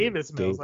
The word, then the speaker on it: Davis Mills: Is it David Mills? Do I have that name right? – It's Davis Mills, actually. – Davis Mills. Davis. Okay, yeah.